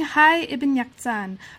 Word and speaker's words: Hayy Ibn Yaqzan: Okay, so Reading Hayy Ibn Yaqzan, A Cross Cultural History of Autodidacticism, and that also came out with Hopkins.